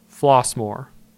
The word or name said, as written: Flossmoor